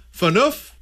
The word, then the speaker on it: Phaneuf: Other names included Dion Phaneuf. Rhymes with goof, according to one responder.